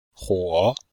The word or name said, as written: huo3